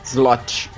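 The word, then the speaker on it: złoty: Slot. Slot pronuncia. É Z-L-O-T-Y.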